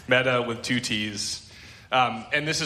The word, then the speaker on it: metta: So this sort of activity, this confession and absolution activity, is rooted in a practice called metta.